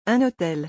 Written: Un hotel

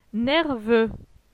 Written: Nerveux

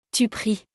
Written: tu pries

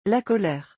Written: La colere

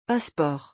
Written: passeport

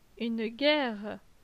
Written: Une guerre

